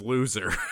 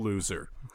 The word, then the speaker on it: Loeser: Daniel, and I want to say his last name is Loser. – How appropriate. – His last name is spelled L-O-E-S-E-R, so I'm going to call him Daniel Loser.